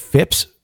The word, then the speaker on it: Phipps: I said his name incorrectly. I should correct that. It's I believe Phipps, P-H-I-P-P-S, mm-hmm.